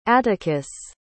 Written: atticus

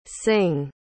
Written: singh

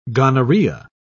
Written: gonorrhea

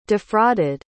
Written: defrauded

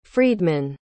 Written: friedman

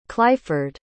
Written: clyford

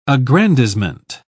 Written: aggrandizement

